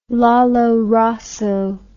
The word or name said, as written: lollo rosso